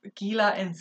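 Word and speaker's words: Gela: But off the coast of Gila in Sicily. – Excellent pronunciation. Great job.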